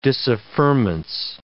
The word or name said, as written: disaffirmance